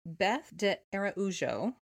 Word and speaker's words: de Araújo: Um, which I don't think we've said yet, which is uh, pronounce it for us. – Yeah, which is Beth de Araujo. Oh, I was going to not do that correctly, so I'm very glad that you did.